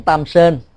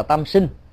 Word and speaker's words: tam sinh: cúng tam sơn nếu đọc đúng âm á, của người miền Bắc á, thì gọi là tam sinh